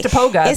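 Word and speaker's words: Istokpoga: Oh, it doesn't give me the sound, but it gives it written out. You say the K. – Istakpoga. – Istakpoga.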